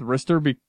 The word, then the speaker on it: Ristar: I've been going with Ristar, Ristar, because that is how Ristar is, in my opinion, pronounced in the BattleTech universe.